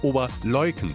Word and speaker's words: Oberleuken: die Betonung ist hinten, bei Leuken. Also es ist nicht Ober oder Unter, ist wurscht, es heißt immer Oberleuken.